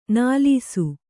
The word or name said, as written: ನಲಿಸು